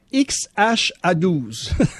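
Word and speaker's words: X Æ A-12: X-A-E-X-2-I. Mais ça se prononce en anglais X-H-A-12.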